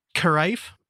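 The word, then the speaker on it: Caraeff: Carif is how I would do it. It's C-A-R-A-E-F-F. – Sure, that sounds right. – So I apologize if that was pronounced wrong. Crafe, who is another famous music photographer.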